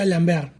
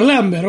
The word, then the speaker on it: Lambert: Y yo me enteré que Christopher Lambert se pronuncia Lambert como Steven Colbert. – Tiene sentido, por favor, pero toda la vida dijimos Christopher Lambert, vos.